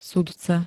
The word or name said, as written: sudca